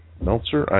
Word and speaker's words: Meltzer: I was watching Brad Mel's, Decoded, Brad Meltzer, I'm not getting his name right, but it's on one of the TV channels there, the documentary channels, and I enjoy the show.